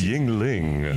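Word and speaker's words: Yuengling: I tried some beer called Jingoey. – Jingoey, sure. – I think that was Yingling.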